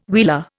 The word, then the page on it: wheeler: Indian